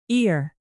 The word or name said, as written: ear